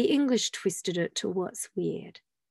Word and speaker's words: wyrd: Now, the word, our English word weird, originally came from the Viking word weird, which is spelled W-Y-R-D.